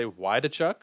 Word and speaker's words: Waldichuk: I think it's Waldichuk. Although I think I've heard some people say Wydechuk, and like that L is silent.